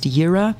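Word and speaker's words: yira (יראה): It means, it's pronounced yira, and that Hebrew word consists of ra, to see, and yod, hand.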